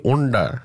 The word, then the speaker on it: Unda: featuring at Yarra Lewin with his album launch called Under Wormer Are You Under. Singing? – Say it right, Andy. Unda.